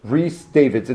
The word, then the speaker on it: Rhys Davids: Rhys Davids. – It's spelled R-H-Y-S.